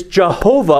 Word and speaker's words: Jehovah: I heard some hard J's there. That was good. That thou, whose name alone is Jehovah, art the Most High over all the earth.